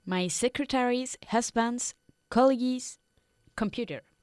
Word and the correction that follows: colleague: But colleague se pronuncia colleague.